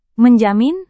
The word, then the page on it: menjamin: Indonesian